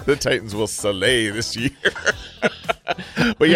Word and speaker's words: Saleh: Robert Saleh. – Saleh, okay.